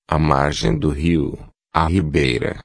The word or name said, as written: margem do rio ribeira